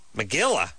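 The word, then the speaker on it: megillah: Megilla? Whose name Megilla? It rhymes with gorilla.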